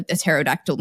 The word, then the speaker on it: pterodactyl: The silent P in pterodactyl, by the way, wasn't silent in Greek, but it is silent in English due to what we call phonotactics, or yeah, like the rules of pronunciation. English doesn't like P's and T's next to each other at the beginning of a word, so we just pretend it doesn't exist.